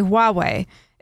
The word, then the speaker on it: Huawei: If you can say Juan, you can say Huawei. It's the same thing. There's no reason the transliteration of, and they put the symbol in there, is Huawei.